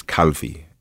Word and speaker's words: Calvi: My family name is Calvi. You spell that C-A-L-V-I. My first name is Mario. That's M-A-R-I-O.